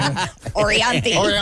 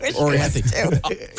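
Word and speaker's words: Orianthi: I don't think I'm saying it right, but Anthree Offrey. It's the Michael, whatever her name. – Orianthe. – Anthree Offrey. I wish her name was Anthree Offrey. – Yeah. Orianthe.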